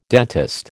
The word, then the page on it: dentist: American English